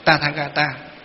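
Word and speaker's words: Tathāgata: Tự Như Lai ở đây trong tiếng Phạm gọi là Tathagata. Ngày xưa chúng tôi học tiếng Phạm là, phiên âm đọc là Tathagata, tức là Như Lai.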